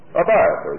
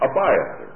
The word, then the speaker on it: Abiathar: Well, it says that one, the only escapee from the massacre of Nob, was one priest by the name of Abiathar. I got his name right? No, a Hittite. I get these fellows mixed. What was his name here? Abiathar, that's right, verse 6, Abiathar. Abiathar.